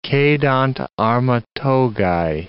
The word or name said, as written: cedant arma togae